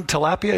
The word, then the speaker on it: tilapia: The second group is called the mushed, and one of the fish within that group is called, you'll recognize this one, tilapia. Anybody like tilapia? Am I pronouncing that right? Is it tilapia or tilapia? Tilapia, okay.